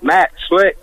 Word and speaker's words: Slick: Am I pronouncing your name right, Matt Slick? – That's it, S-L-I-C-K, like an oil slick.